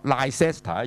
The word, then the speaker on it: Leicester: 下面這個就在香港、上面一個就在九龍、香港其實我們有過羅士打行嘛、但呢你如果你說照聘呢你說 Gloucester 過羅士打道嘛、 Gloucester Road 呢你又錯了、跟那個 Leicester 一樣其實個 C 字呢、是不發音的中間的 C 字、所以實際上這條路呢就叫做過羅士打、就叫 Gloucester、死不死 Gloucester。